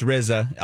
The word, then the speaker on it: RZA: It's RZA. All right. – I like RZA. – And then Gizza, but it's spelled RZA. – RZA, yeah. But pronounced R-I-Z-Z-A. – Okay, yeah.